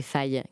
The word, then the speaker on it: Faye: On devrait dire Faye, comme paye. Je sais pas, ça s'écrit pareil. Et donc, c'est Faye. – Eh ben, c'est Faye, Gaël Faye.